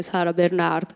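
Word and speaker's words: Sarah Bernhardt: Faccio riferimento a Sara Bernard, in realtà l'accento sarebbe Sara Bernard,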